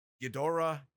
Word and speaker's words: Ghidorah: And, so wait a minute, how do you pronounce it? Ghidorah? – Ghidorah, King Ghidorah.